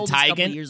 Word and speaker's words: Teigen: oh, is it Tigan? Oh! everyone is mispronouncing her name. That it's actually Tigan. – It's like Tiger.